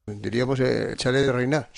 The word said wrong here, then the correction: Reynard: Chalet Reynard. – Sí, sí, sí.